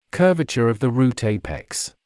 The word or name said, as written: curvature of the root apex